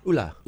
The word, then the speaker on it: Ulah: Am I saying it correctly? – Yeah, Ulah. Ulah. U L A H. That's right.